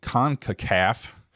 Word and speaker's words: CONCACAF: Four or five years, but they did qualify for what's called the CONCACAF.